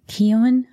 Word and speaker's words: Keown: And then there's another book called Murder at the Hotel 9/11 by Audrey Keown. I believe is how you say the last name. It's K-E-O-W-N.